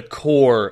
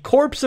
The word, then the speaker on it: Corps: Even though every motherfucker in that game refers to it as the Corpse of Discovery, because, you know, if you spell core, like the Army Corps is spelled C-O-R-P-S, right? But you don't pronounce the fucking P because you're not an asshole.